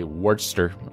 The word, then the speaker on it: Worcester: Say Worcester in this instance because it's not spelled like the sauce, which is Worcestershire.